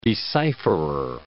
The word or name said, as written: decipherer